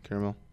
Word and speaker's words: caramel: Caramel. – Mm-hmm. Caramel. There's a silent A.